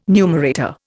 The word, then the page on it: numerator: Indian